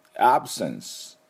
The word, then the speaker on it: absence: 欠席はですね、 absence と言いますね。まあ、名詞ですね。で、 absence です。 absence。もう一回言いましょう。 absence ですね。で、 absence と発音しますが、最初の a の音がポイントですね。